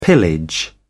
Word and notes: pillage: British English

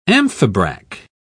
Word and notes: amphibrach: British English

